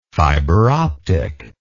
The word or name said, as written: fiberoptic